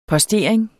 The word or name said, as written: postering